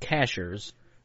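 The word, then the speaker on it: Cashiers: It's North Carolina, but it's pronounced Cashers in North Carolina.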